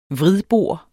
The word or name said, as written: vridbor